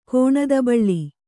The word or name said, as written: ಕೋಣದಬಳ್ಳಿ